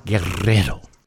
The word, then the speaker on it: Guerrero: I'm Dan Guerrero, or if you can roll your R's, Guerrero.